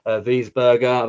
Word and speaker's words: Wiesberger: Wiesberger, I've actually said his name right.